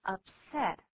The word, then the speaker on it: upset: 对，当形容词的时候，重音在第二个音节，念作 upset。